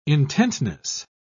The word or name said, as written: intentness